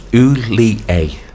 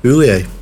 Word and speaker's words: Hollier: Yes, pronounce Oulié. – Oulié. All right. – Oulié. Yeah. – I was just like Gerard. – Apparently so, yeah. That's what it says on his website.